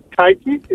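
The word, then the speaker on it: Taigi: Okay, my question is, Blessed Anna Maria Teige, is that yes.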